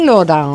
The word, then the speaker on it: Laurent: Ο Λισιέν Λοάν. Μεξικάνοι. – Μεξικάνοι. – Το φάγανε. – Ναι, αφού είπα Γαλλία-Μεξικό. – Ναι. – Θα το πούμε προφορά ξανά. Ο Λισιέν Λοράν.